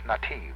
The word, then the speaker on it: nativ: And that word is nativ. And it's spelled N-A-T-I-V, almost like native, except no E on the end, nativ.